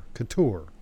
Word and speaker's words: Cattoor: His last name is spelled C-A-T-T-O-O-R. And, you know, oddly enough, I would have thought it's pronounced Couture. – Yeah. – And the emphasis is on the tour. Couture.